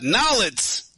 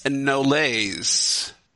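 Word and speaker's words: Nolet's: So last episode I was talking about Nollet's gin. Apparently, I wasn't pronouncing it pretentiously enough. – Mm. – The correct pretentious pronunciation is Nolays.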